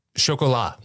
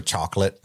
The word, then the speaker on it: Chocolat: Chocolat. What is chocolat? – Correct. – Yeah. – Who said it wrong? Was it Steve? He just said, I remember chocolate. – No, Chris definitely said it wrong. Because I knew it was chocolat.